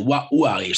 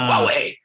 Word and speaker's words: Huawei: And when I pronounced the name of the company, I said, like you said, Huawei or something like that. They're like, it's called Huawei.